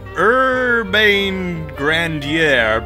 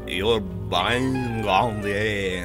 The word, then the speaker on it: Urbain Grandier: The French pronunciation is something like Urbain Grandier, and I can't do that, so I'm just going to call him Urbain Grandier.